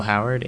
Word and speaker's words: Howard: My name is Neil Howard, H-O-W-A-R-D.